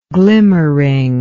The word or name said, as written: glimmeringly